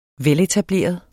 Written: veletableret

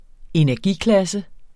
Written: energiklasse

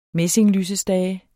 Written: messinglysestage

